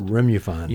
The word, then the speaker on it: Remofan: You're speaking of that USA Today investigation into that dietary supplement that's spelled Remofan. It's pronounced Remufan.